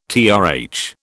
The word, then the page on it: TRH: British English